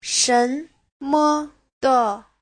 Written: shen2me5de5

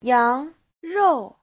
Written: yang2rou4

